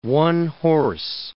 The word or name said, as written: one-horse